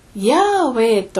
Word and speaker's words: ヤーウェイ: ところが今は多くの学者たちがヤーウェイと発音されていたに違いないと考えています。